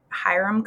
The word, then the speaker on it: Hiram: You are a professor of psychology at Hiram College.